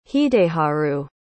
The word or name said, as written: hideharu